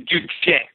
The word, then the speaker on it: Juche: how to correctly pronounce what's actually Juche.